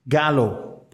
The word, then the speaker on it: Gallo: No, e, en inglés y la pronunciación en inglés es galo, pero hay mucha gente que le dice yo y gallo, pues, pues cómo se escribe.